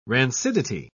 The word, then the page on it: rancidity: British English